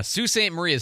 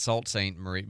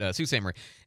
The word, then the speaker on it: Sault Ste. Marie: And Sault Ste. Marie is how you say that, by the way. Not Salt St. Marie, but Sault Ste. Marie.